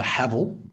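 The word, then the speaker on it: Haval: I am driving the Havel, which rhymes with gravel.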